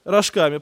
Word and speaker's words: рожками: И он рад был наполнить чрево своими рожками, которые ели свиньи, но никто не давал ему рожками». Правильно, помечайте правильно, рожками, да. Тут даже ударение стоит в Библии, рожками.